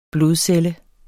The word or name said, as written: blodcelle